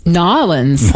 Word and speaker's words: New Orleans: Is it New Orleans, New Orleans, or as they say? – Nolens. – Nolens, exactly. They make it one syllable.